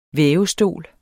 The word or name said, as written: vævestol